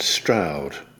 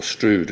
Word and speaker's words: Stroud: Well, I was in High Wycombe last night, and I'm in Stroud, or Strood, as some people pronounce it, in Gloucestershire tonight.